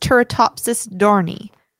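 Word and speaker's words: Turritopsis dohrnii: I'm gonna say it's pronounced Turritopsis dorni. – Turritopsis dorni. – Dorni. Like, yeah, because you would just silent the H. – Okay. – But it would make it a hard O.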